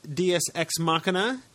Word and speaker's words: Diaz Ex Machina: Well, it's the Machina.